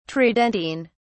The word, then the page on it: tridentine: British English